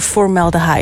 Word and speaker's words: Formaldehyde: Je maakt zo meteen kennis met hun gloednieuwe singer Formaldehyde? – Ja, dat is perfect uitgesproken eigenlijk.